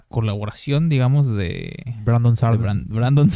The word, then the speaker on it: Brandon Sanderson: que si dices Brandon, cuesta, pero si es Brandon Sanderson. Brandon Sanderson. Ajá. – Tienes que decirlo con la pronunciación en inglés.